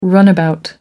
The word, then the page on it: runabout: American English